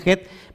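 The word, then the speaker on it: Het (ח): y la letra Het en el hebreo, que se pronuncia Het, vale 8.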